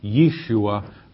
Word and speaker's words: Yeshua: The correct pronunciation is Yeshua, which is the Hebrew name for Jesus, transliterated, which means it's spelled out to phonetically as much as possible sound like the Hebrew word.